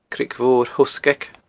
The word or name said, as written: Creag Mhor Thoghsgaig